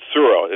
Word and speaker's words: Tsuro: And one final one for him that he would probably enjoy playing with Mike and Carla there is a game called Suro. It's spelled T-S-U-R-O, and